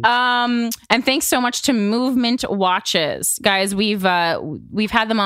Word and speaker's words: MVMT: It's spelled MVMT, but it's pronounced Movement.